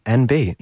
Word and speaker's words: enby: NB, although it's spelled E-N-B-Y, it is NB, non-binary.